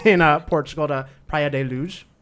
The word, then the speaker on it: Luz: And then where the disappearance completely takes place is on vacation in Portugal to Praia de Luz. Did I pronounce so many things Praia wrong? – I think it's like Luge. – The Z is like Luge.